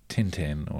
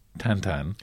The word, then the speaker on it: Tintin: science and if I look at things like Tintin or as they're supposed to be pronounced Tantan yeah.